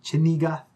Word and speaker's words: Chenega: You could also look up Chenega MIOS, and Chenega is pronounced like your chin, Chenega, and then MIOS.